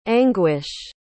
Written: anguysh

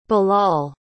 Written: bilal

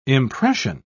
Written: impression

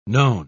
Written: known

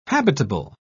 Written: habitable

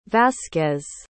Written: vazquez

